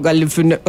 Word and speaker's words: Galifianakis: Sí, yo le saco solo la A, que es como supuestamente se pronuncia, pero es como Galifinaki, o sea, como un poquito más cerradito el asunto.